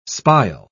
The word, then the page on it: spile: British English